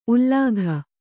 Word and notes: ಉಲ್ಲಾಘ: ullāgha

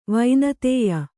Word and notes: ವಿನುತ್ಯ: vinutya

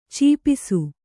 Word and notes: ಛಪ್ಪಿಸು: chappisu

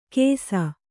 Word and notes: ಕೆಸ: kesa

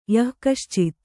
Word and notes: ಯಃಕಶ್ಚಿತ್: hay kaścit

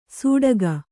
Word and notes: ಸೂಡಗ: sūḍaga

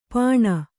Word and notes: ಪಾಣ: pāṇa

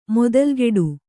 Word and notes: ಮೊದಲ್ಗಿಡು: modalgiḍu

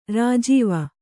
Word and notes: ರಾಜೀವ: rājīva

